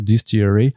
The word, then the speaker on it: DSTLRY: Euh, enfin, c'est D S T L R Y, mais en vrai, ça se prononce donc Distillery.